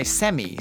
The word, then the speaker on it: semi: S-E-M-I. We pronounce the I. Semi. – S-E-M-I. Semi.